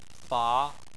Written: fa2

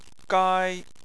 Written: gai1

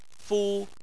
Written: fu1

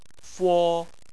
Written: fo1

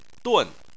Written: dun4

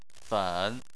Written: fen3

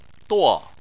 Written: duo4

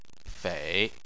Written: fei3

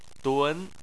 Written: dun2

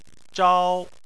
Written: zhao1

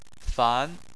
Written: fan2

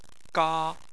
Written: ga1

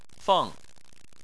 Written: feng4

